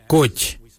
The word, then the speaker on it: goodj: Karli is spelled G-I-I-L-Y-I, karli. For the traditional stone axe, we say koch, koch. Koch is spelled G-O-O-D-J, koch.